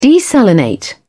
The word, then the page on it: desalinate: British English